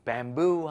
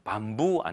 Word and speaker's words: bamboo: Bamboo 하면은 바로 대나무를 의미하거든요. 그런데 이거 발음 주의하셔야 됩니다. Bamboo 하면은 밤부 아니고요. Bamboo.